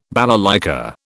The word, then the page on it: balalaika: British English